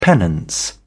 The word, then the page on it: penance: British English